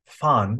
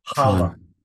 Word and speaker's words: Van Halen: And by the way, you know how to pronounce the family name? It's Fon, almost like a soft F. Hala. – Hala. Hala. – Yeah. In Dutch, you almost always soften and drop the N and then so it's Fon Hala.